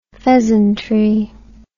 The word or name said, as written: pheasantry